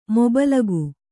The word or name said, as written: ಮೊಬಲಗು